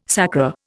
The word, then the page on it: sacra: Indian